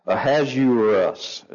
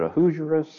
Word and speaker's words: Ahasuerus: And so I actually tried to, and it's Ahasuerus is how it's actually pronounced. But I've always heard it Ahasuerus, so I really have a hard time to go back to Ahasuerus.